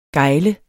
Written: gejle

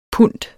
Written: pund

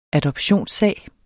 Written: adoptionssag

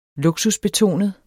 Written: luksusbetonet

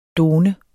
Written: DONERNES

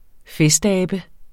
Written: festabe

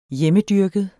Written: hjemmedyrket

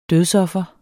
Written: dødsoffer

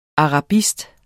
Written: arabist

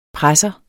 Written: presser

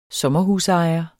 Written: sommerhusejer

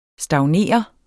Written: stagnere

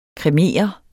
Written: kremere

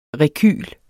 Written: REKYLEN

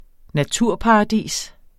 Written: naturparadis

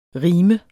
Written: rim

